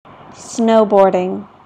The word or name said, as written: snowboarding